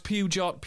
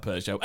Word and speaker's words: Peugeot: I was tempted to try and do a funny joke about he always pronounced Pugot Pugot. but I realised if you can't see it, you wouldn't know I was talking about Peugeot.